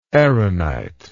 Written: aaronitic

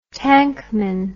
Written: tankman

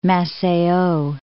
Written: Maceio

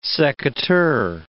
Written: secateur

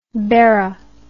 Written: Berraism

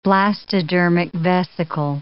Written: blastodermic vesicle

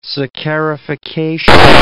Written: saccharification

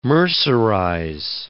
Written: mercerize

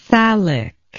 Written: thallic

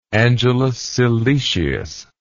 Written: Angelus Silesius

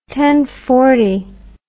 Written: tenfoot

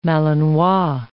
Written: Malinois

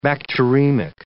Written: bacteremic

